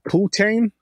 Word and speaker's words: poutine: Oh, no, that's the English pronunciation of it. If you're doing the French one, it's poutine.